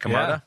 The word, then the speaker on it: Camarda: Is that how you say it? Camarda? – Yeah.